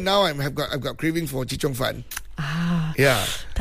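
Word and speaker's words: chee cheong fun: I mistyped and mispronounced the name. Uh-huh. And they said, bro, it's called chichong fan.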